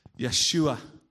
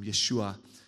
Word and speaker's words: Yeshua: Yeshua, which is what his name would more likely have been pronounced at the time, Yeshua.